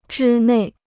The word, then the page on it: 之内: zhī nèi